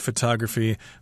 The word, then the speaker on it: photography: but in the word photography, the accent is on the second syllable.